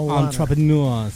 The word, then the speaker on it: entrepreneurs: Entrepreneurs, Entrepreneurs. Se pronuncia entrepreneurs.